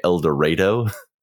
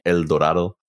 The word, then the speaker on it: El Dorado: but they kept calling it El Dorado, Texas. – Yeah. – And I'm just like, it's a Spanish word. It should be El Dorado.